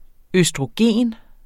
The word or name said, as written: østrogen